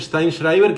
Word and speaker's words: Steinschreiber: lo que pasa es que desconcierta cómo se escribe, porque se escribe Steinschreiber, pero se pronuncia Steinschreiber, que quiere decir el que escribe en la piedra, en alemán.